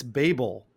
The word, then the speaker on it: Babel: It turns out that the way that you pronounce the episode of Deep Space Nine's fourth episode, I've been getting it wrong for 30 years. It's Babel, like the Tower of Babel, Journey to Babel. – Oh. – And I had said it as Babel, like baby Babel.